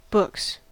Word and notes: books: American English

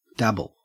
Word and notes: dabble: Australian English

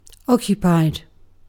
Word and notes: occupied: British English

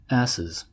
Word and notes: asses: American English

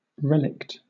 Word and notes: relict: British English

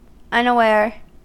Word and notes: unaware: American English

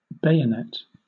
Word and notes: bayonet: British English

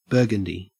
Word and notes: burgundy: Australian English

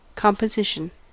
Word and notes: composition: American English

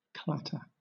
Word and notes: clatter: British English

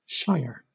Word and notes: shire: British English

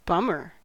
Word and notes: bummer: American English

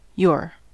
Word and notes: yore: American English

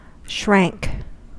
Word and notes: shrank: American English

